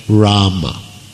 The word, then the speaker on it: Rama: Well, in the Sanskrit mantra, the correct pronunciation is Hare with a ha and Rama.